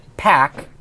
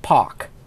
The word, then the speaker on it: Paak: I think it's pronounced Park. – You know, we checked on this, and his mother is Korean. His last name is Anderson. This is exactly how he describes it. So it's Park is actually the name. He pronounces it Pack, but it's really pronounced Pock.